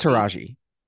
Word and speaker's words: Taraji: Everyone loved Cookie, who to Rosh to I feel like I'm saying her name wrong. – I think it's Taraji. – Taraji.